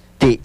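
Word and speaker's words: ti': Y un apóstrofe o glotal. Muy bien, excelente. Y se pronuncia efectivamente como ti.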